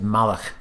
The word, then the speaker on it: Malloch: Although it's actually a Scottish name, so if you were from Scotland, you'd say Malloch.